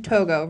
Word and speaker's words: Togo: So, like you said, you are originally from Togo, right? – Yes, that's how you say it?